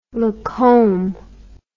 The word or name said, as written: Lacombe